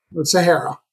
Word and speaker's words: Sahara: So is it Sahara or Sahara? – Sahara. – Sahara, like the desert.